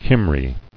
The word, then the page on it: Cymry: Cym·ry